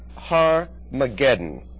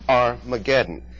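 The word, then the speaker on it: Har-Magedon: Har is a Hebrew word for mountain. Har-mageddon. And we often pronounce it Armageddon.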